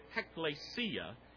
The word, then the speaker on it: ekklesia: Ekklesia, we usually pronounce it, ekklesia.